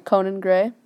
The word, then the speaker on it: Conan Gray: Is that how you say his name? – Conan Gray. Yeah, you listen to him.